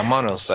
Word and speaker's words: monos: I mean, the other thing that really surprised me, and my apologies for my pronunciation here, is these little dolls, or monos? – Yeah, I think you're right. I actually have never heard it pronounced myself, but you've done very well with all the Spanish terms. High marks. But yeah, monos. I think, yeah, you said it right, monos.